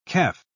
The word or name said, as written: kef